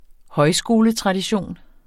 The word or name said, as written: højskoletradition